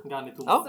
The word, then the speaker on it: Ganitumab: Ganitumab. – Ja. Exakt, jättebra uttal förresten.